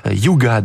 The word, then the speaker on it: hygge: Objectif zéro stress, zéro charge mentale professionnelle pour atteindre ce que l'on appelle dans ces pays le yoga, qui s'écrit H-Y-G-G-E, Yuga, donc concept danois encore qui permet d'atteindre une sensation d'apaisement, un état d'esprit à la fois positif et chaleureux.